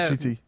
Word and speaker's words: Umtiti: Umtiti? – Yeah. – Umtiti.